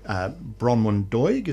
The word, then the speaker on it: Bronwyn Doig: And as you've mentioned already, you had people like Bronwyn Doig, is that how you pronounce her name? Yep.